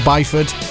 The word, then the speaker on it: Byford: Mr. If Bifford, or Byford, how we pronounce it.